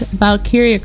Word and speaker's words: Valkyria: But it's spelled V-A-L-K-Y-R-I-A. So I think it's Valkyria Chronicles.